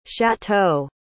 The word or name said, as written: chateaux